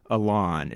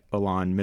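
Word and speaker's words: Ilan: We've got Anthony Michael Hall, of course, we mentioned, and uh, I, am not quite sure how to pronounce this guy's first name. I think it's Alon. It's it's I L A N. He's the guy that played Wyatt. Alon Mitchell Smith.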